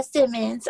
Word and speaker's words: Simmons: Yeah, Simmons.